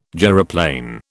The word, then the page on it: gyroplane: British English